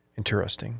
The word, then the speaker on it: interesting: Yeah, I mean, as it's spelled, it's technically a four-syllable word, interesting. But people just combine them.